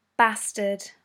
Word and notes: bastard: British English

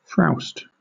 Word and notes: frowst: British English

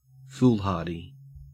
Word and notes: foolhardy: Australian English

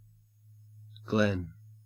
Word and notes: glen: Australian English

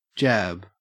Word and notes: jab: Australian English

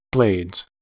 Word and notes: blades: American English

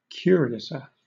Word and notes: curiouser: British English

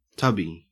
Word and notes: tubby: Australian English

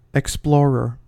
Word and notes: explorer: American English

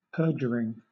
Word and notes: perduring: British English

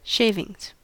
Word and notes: shavings: American English

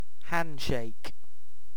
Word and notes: handshake: British English

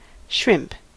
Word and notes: shrimp: American English

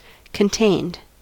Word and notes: contained: American English

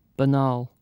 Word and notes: banal: British English

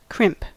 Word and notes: crimp: American English

